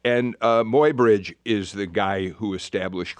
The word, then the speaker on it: Muybridge: Why in your book, is it Muybridge? Is that how you pronounce it? – Muybridge, yeah.